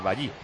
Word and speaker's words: Bailly: No, le da en la cabeza a Bailey. – Sí, sí. Ahora en la cabeza. – A Bailey, ¿no? Que es como dice Juan igual. Sí. Que hay que pronunciar, es Bailly.